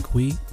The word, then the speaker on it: Cui: And of course, you can find me, Brian Kui. My last name is spelled C-U-I on LinkedIn.